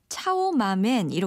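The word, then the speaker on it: 차오마멘 (炒码面): 그러니까 중국 발음으로 하면 차오마멘 이렇게 돼요.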